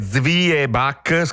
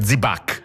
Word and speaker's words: Zwieback: Si scrive Zwieback, scritto con la V doppia e tutto il resto, ma si sente spesso dire Zibac, scritto proprio così anche. – Zibac. – Molti lo scrivono così, Zibac. Z-I-B-A con l'accento C. – Esatto, la pronuncia si semplifica, si ticinesizza, perde un po' di spigoli tedeschi e diventa Zibac, come hai detto tu.